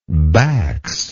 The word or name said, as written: Bax